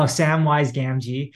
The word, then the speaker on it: Samwise Gamgee: So Piyo mentioned Sam-gi's white, I forget how you pronounce it, but Sam-gi's wise Jin. – Samwise Oh, Samwise Gamgee.